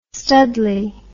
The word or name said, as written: studly